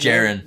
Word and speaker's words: Jaren: Because to start, fifth round pick, Jared Hall. – What's his Jaren.